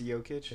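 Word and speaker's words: Jokić: I never heard anybody mess that one up, but he's Jokic. – Been kind of... – That one's pretty easy. – Yeah. – Wait, did you say Jokic? – Yeah. – You actually mispronounced it. – It's Jokic? – It is Jokic.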